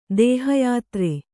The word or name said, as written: ದೇಹಯಾತ್ರೆ